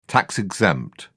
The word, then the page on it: tax-exempt: British English